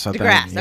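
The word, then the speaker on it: De Grasse: Am I saying that right? – DeGrasse. – Okay, no, DeGrasse. I'm sorry. I'm making it French.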